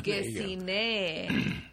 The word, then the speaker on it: Gesine: Well, so my name is spelled G-E-S-I-N-E. – Which is why we all say Gassini. – That's right. – Yeah. – But the E in German is an A. – Ah. – Gassine.